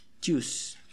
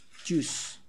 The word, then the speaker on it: Jews: La siguiente es la palabra que significa judíos y sería Jews, Jews, Jews. Intenta poner la lengua en tu paladar antes de pronunciar la palabra y ya después sueltas el aire, como juice, juice, ¿sale?